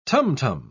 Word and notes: tam-tam: British English

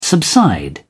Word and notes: subside: British English

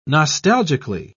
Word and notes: nostalgically: British English

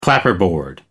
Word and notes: clapperboard: American English

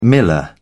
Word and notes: miller: British English

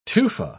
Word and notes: tufa: British English

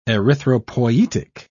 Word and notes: erythropoietic: British English

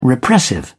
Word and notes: repressive: British English

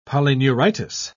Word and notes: polyneuritis: British English

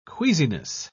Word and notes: queasiness: British English

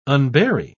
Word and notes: unbury: British English

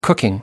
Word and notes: cooking: American English

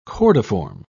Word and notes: cordiform: British English

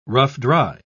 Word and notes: rough-dry: British English

